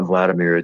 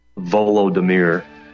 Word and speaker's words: Volodymyr: But by the way, you mispronounced Zelensky's name. It's not Vladimir. It's Volodymyr or Voldemort.